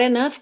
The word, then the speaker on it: enough: Então, hot enough com rarara na ligação desse T. E lembra da pronúncia dessa palavra, enough, E-N-O-U-G-H, enough, tá?